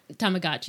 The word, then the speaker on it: Tamagotchi: Tamaguchi? Am I saying it right? – Tamagotchi. You got it first.